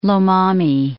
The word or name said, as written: Lomami